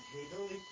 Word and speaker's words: Haeberle: My name is John Haberle, H-A-E-B-E-R-L-E, licensed architect in New Jersey, New York, Pennsylvania.